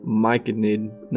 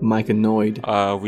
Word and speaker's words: myconid: I mean, it took me even longer to realize that it's pronounced myconid, not myconoid.